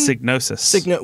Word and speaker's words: Psygnosis: Psygnosis. – With a P. – Yes, with a P. – Okay, so that was their... That was P-S-Y-G-N-O-S-I-S.